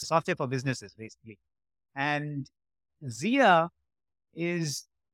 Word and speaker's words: Zia: Am I pronouncing it correctly, Zia? – Yes, you're pronouncing it right.